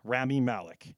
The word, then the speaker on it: Rami Malek: Rami Malek.